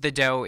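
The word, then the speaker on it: Dø: It is pronounced The Doe. It is spelled D O, and the O has kind of a slash through it.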